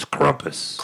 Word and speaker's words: Krampus: That's what people say, and I also wanted to mention that you pronounced my name wrong at the beginning. – Scrumpus, is that it? – No, it's Krumpus. – Krumpus, I'm sorry. – The U and the A, in America, you have changed it to AU, so you said Krampus, but it's actually Krumpus.